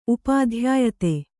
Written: ಉಪಾಧ್ಯಾಯತೆ

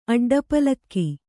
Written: ಅಡ್ಡಪಾಲಕಿ

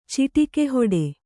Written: ಚಿಟಕಿಹೊಡೆ

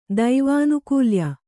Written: ದೈವಾನುಕೂಲ್ಯ

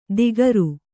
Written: ದಿಗರು

